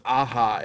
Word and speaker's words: Aja: I do think it's Aja, isn't it? Because he's Hispanic, right? – Oh, I think you're right. – Alexandre Aja. – Yeah, I was pronouncing it like the Steely Dan album.